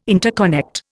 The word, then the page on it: interconnect: Indian